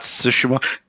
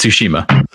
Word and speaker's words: Tsushima: it's not Tsushima. No, it's it's Tsushima.